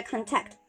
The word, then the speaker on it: contact: 然后这个 contact，contact，那这个 contact 我觉得也是大家可以去学习的一个词啊。我们可能见的比较多的是它的动词的用法，对吧？啊，我们如果是动词的用法的话，一般发音我们会把重音放到这个后面啊，这 contact 啊，对，就是这个动词的用法。